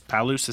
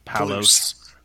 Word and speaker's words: Palouse: Palouse, I said it right? – Palouse. – Palouse.